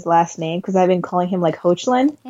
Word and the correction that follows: Hoechlin: It's Hecklin. Hecklin.